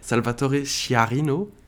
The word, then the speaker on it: Salvatore Sciarrino: j'essaye de bien dire Salvatore Sciarrino, c'est comme ça hein.